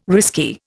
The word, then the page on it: risky: Indian